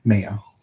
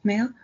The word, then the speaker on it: Mello: M-E-L-L-O? – Mayo. – Mayo? – Mm-hmm. – Okay.